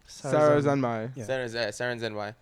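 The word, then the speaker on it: Sarazanmai: How do you pronounce the title again? Sarazamon? – Saren Zenmai.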